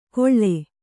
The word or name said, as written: ಗೊಳ್ಳೆ